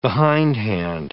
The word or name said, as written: behindhand